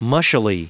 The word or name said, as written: mushily